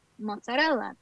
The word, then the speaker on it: mozzarella: So to me, mozzarella, that's how you say it.